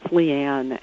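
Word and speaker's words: Leighann: It's L-E-I-G-H-A-N-N. It's Leanne.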